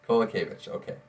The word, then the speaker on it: Kowalkiewicz: Kowalkiewicz? No, I'm off. Okay, I'm not going to try the second. – Kowalkiewicz. – Okay, I gotta practice that.